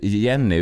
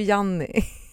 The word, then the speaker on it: Janni: Nej, det spelar inte så stor roll, men man kan, vi kallar henne Jenny, uttalar det rätt? – Nej, men hon heter ju Janni.